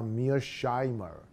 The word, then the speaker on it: Mearsheimer: 就是纠纠正我那个音，那叫 Mearsheimer。